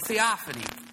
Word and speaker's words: theophany: So it's theophany.